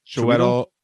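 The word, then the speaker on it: Chiwetel: Uh, it stars Bill Nighy, Colin Firth, Liam Neeson, Emma Thompson, Martin Freeman, uh, I always pronounce his wrong his name wrong. Ch- Chuetel? – Chuetel Ojifor.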